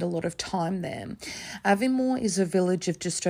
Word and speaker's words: Aviemore: exactly how to say it, Aviemore, which it is the way it's spelled.